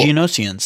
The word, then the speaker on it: Geonosians: They're fighting the droids and the Geonosians. I think I'm pronouncing that right. – It sounds like Genesis, but with a different vowel.